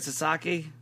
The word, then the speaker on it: Sasaki: You know, and then Sasaki gets, is that how you say it, Sasaki? – Yeah.